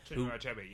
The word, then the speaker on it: Chinua Achebe: And of course, one of the most striking essays on Heart of Darkness is by a man called, and forgive my pronunciation, Chinua Achebe. – Chinua Achebe, yeah.